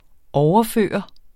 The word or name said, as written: overføre